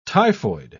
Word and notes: typhoid: British English

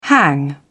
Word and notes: hang up: British English

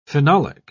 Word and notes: phenolic: British English

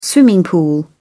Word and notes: swimming pool: British English

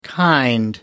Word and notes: kind: American English

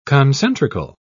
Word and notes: concentrical: British English

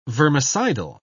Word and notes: vermicidal: British English

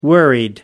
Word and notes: worried: American English

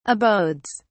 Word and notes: abodes: British English

